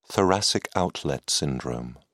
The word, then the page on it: thoracic outlet syndrome: British English